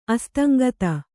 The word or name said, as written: ಅಸ್ತಂಗತ